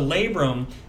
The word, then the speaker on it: labrum: But the labrum is a cartilage ring that sits around, the outer rim of the socket.